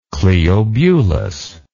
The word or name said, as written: Cleobulus